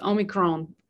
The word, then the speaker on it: Omicron: Angelo Gus says, y'all are all saying it wrong. It's Omicron.